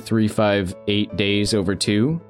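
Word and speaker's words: 358/2 Days: No, because I remember one of my favorite videos of yours was when you were examining 358 days over 2.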